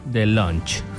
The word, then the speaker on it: DeLonge: Esta banda de rock llamada Blink-182, con el vato que hoy ve ovnis, Tom DeLonge. ¿Se pronuncia así? – Sí. – DeLonge.